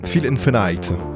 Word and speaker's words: Feel Infinite: Phil Infinite, si je le prononce bien à l'anglaise, comme toi euh, Clément, tu es bilingue. – Tu étais parfait.